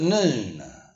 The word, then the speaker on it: nun: And when we get to this verse in 105, we find the alphabet called noon. N U N. It's pronounced noon.